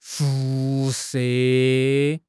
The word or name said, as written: 辐射